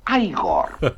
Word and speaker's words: Igor: No, se pronuncia Igor.